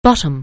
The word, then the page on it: bottom: anglais